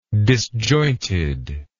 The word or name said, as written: disjointedness